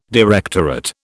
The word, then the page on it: directorate: British English